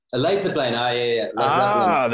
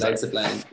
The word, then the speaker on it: Leidseplein: And we went to some underground rave like right outside of the red light district off of like not Liedenspleen, or is that how you say it? – the Waterlooplein. – Is that how you say it? Leidseplein? – Leidseplein. – There it is. – Leidseplein.